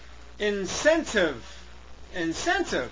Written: incentive

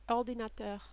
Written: ordinateur

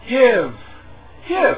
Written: give

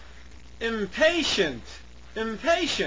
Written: impatient

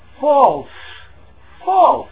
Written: false

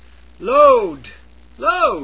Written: load

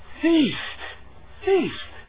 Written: feast

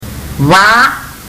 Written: va

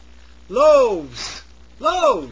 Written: loaves

